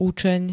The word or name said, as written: učeň